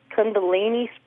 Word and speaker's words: Kundalini: Wondering if you could tell me anything about the, I don't know if I'm saying it right or not, or pronouncing it right, Kundalini spirit. – Yeah, you did a good job.